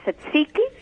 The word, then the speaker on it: tzatziki: It's pronounced, I believe, tzatziki. – Yes, I think you're right.